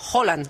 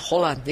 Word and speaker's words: Haaland: que él quiere que le llamemos Haaland, que esa es otra. – Haaland. – Sí, sí, lo dijo en la pronunciación de la FIFA, dijo, my name is Haaland, y digo, oh, vale, ok.